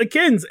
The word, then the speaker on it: Akins: He didn't have a Wikipedia, which made me feel, it's just Akins, A-K-I-N-S. – So it's essentially how Michiganders pronounce the name anyways, because we never say the T in Atkins.